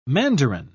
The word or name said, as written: mandarin